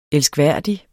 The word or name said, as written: elskværdig